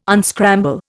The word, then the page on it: unscramble: Indian